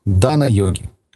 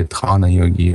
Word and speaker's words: дхана: Дальше идут дана-йоги, или дхана-йоги, иногда говорят. Да? Хэ, оно мягкое в санскрите, так читается. Дана-йоги, дхана-йоги.